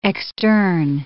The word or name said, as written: extern